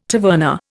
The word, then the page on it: taverna: Indian